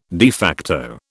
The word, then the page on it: de facto: British English